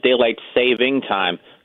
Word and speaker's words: daylight saving time: I'm actually very impressed you said daylight saving time, because that's the correct pronunciation. Almost everywhere else I hear acceptable. – Daylight savings time.